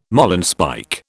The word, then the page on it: marlinspike: British English